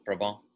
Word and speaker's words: Brabant: I don't think Brabant is actually the pronunciation. I take my liberties. It's Brabant. I almost wanted to go French with it, but he informed me that that's not the case.